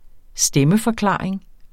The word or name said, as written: stemmeforklaring